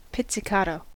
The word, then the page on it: pizzicato: American English